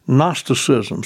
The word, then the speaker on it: Gnosticism: Now, there was a heresy, as I said, we know as Gnosticism. Starts with a G, a silent G.